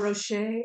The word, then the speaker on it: Rocher: Rocher.